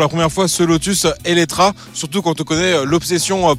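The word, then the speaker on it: Eletre: Et oui, je dis Eletra, hein, même si ce nom de modèle il se termine par un E, car en fait c'est la prononciation en hongrois, et d'ailleurs ça veut dire qui vient à la vie tout un programme.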